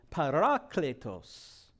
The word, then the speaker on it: parakletos: Now, if we were to pronounce this in the Midwestern dialect, we would say parakletos. Parakletos. And if we used it in a sentence, we would say, I need to get a parakletos for my boys' football season this year. Parakletos. So you choose whichever dialect you want to pronounce it in, the Greek or the Midwestern.